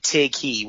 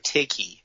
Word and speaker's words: Tighe: Kevin, K-E-V-I-N. – That's all I needed. – T-I-G-H-E. Tighe. We'll call him Mr. Tighe.